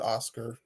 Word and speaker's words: OSCR: OSCR. They call it Oscar. It's acronym OSCR.